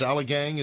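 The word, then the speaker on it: Ommegang: Oh my gosh, it's Allagang. Is that one of your events? – Yeah, actually. – Did I even say that right? – You said it perfectly.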